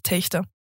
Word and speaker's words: Telgte: Also die Leute, die dort wohnen, wissen, wie es ausgesprochen wird. Also der erste Versuch bei vielen ist dann Techte. – Ja. – Wenn man aber dort wohnt, wird es Techte. Techte. Mit dem CH, genau, okay.